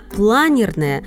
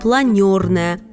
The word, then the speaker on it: Планерная: Станция московского метрополитена «Планерная» со дня открытия в течение 20 лет объявлялась как «Планерная», однако позднее укоренилось название с ударением на первом слоге, хотя официально станция никогда не переименовывалась.